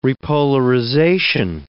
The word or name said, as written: repolarization